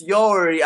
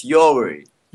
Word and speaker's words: Yoweri: It's Yoweri. I think Yoweri should be better for you. It's Yoweri.